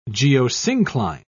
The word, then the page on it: geosyncline: British English